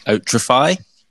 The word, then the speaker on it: Otrafy: We've got Lucas here, who is the co-founder of a company called Otrafy.